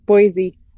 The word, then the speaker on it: Boise: Yeah, Boise, Idaho.